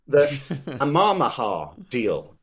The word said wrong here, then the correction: Al Yamamah: It's called It's called the Al Yamamah deal. It's, I believe, Arabic for dove. The Al Yamamah.